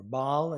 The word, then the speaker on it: Baal: You may have heard of the Arch of Baal, or Baal as it's sometimes pronounced.